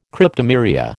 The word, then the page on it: Cryptomeria: American English